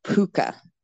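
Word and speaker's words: púca: So in Irish, in like Gaelic or Gaelga, the word for a ghost is called a puka, and that's spelled P with one of those U father CA.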